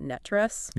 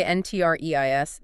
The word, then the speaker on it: NTREIS: And by the way, if you want to sound like a true local insider, you pronounce that NETRES. – Yeah, please don't spell it out. – Right. If you say NTREIS, they will look at you funny.